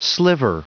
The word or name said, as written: sliver